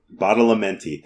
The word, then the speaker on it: Badalamenti: The first track is by Angelo Badalamenti, and I'm sure I've pronounced that wrong. – Badalamenti, thank you. I was trying to be respectful, and it didn't work that way. – We've mispronounced any number of names on Pure Moods already.